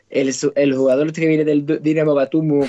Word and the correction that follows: Batumi: como se llame, Batumi.